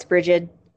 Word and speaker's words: Brigid: And do you actually know, because you know more Gaelic than I do, it's spelled Brigid with a D, but is it pronounced just Bridget? – No, it's Bridget.